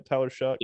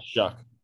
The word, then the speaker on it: Shough: Tyler Shuck, I'm pretty sure I'm pronouncing that correctly. Is that right, Tyler Shuck? – Yeah, Shuck.